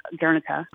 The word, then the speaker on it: Guernica: So the play, Picasso Presents Guernica, is part of it is Picasso explaining the painting, which I think is normally pronounced Guernica, which is the Spanish, and that's that very famous painting in Madrid.